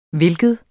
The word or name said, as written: hvilken